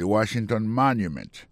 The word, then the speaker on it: Washington Monument: mot Washington là accent tombé sur premier syllabe là pour mot monument il tombé sur premier syllabe là de Washington Monument monument c'est comme ça on dit monument en anglais alors place ça c'est place monument ou c'est obélix là.